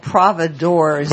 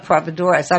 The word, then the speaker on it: Providors: Peter, I mispronounced it. It's called Providors. – Providors. – Not Providoras. I'm thinking Spanish.